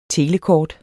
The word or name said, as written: telekort